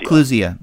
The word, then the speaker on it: Clusia: Okay, so I pronounced it wrong. Clusia. – Clusia, yes, it's Clusia.